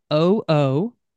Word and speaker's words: Karoo: Now, my first thought, of course, is the great hitter from the Minnesota Twins, California Angels, Rod Carew. Carew, but that's not how Karoo is spelled. How is it spelled, and where do they come up with the name? – Karoo is Karoo, and it comes from the sandhill cranes that are often seen throughout the course there.